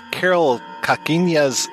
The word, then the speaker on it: Karel Kachyňa: This week we are looking at Karel Kachyňa's The Ear.